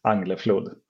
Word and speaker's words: Angleflod: Yeah, so in Swedish, I'm Swedish. My name is Jonas Angleflod. In the American version, is like Jonas Angleflod.